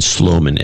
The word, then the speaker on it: Sloman: Sloman? Is that what it is? Colin Sloman? – Yeah, Colin Sloman, S-L-O-M-A-N.